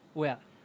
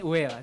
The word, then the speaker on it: Ueda: ya, aprendiste cómo se dice su apellido. Wea. ¡No! – no, ah. – Masami Ueda. Ya, sí, ya entendí, ya entendí, ya entendí, entendí, sí, ya Pero por favor, cuéntanos un poco más acerca del señor Ueda.